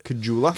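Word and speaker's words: Caggiula: I never pronounce his name wrong, but they trade Drake Kagula. Is that right? – Kajula.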